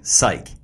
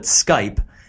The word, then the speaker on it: Psych: Psych. – You want to spell that out for our listeners here? – Okay, in case you didn't catch in the beginning, I mispronounced it and I called it Skype